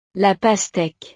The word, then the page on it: la pasteque: frances